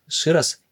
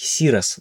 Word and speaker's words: Ширас: Ширас или, как по-тамильски, Сирас даже.